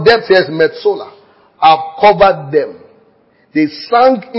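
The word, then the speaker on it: metsola (מְצוֹלָה): The word metsola, the depths, depth. Pronounce the word depths, metsola, M-E-T-S-O-L-A-H.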